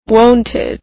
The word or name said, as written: wonted